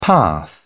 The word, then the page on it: path: British English